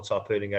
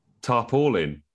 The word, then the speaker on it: tarpaulin: Tarpaulin.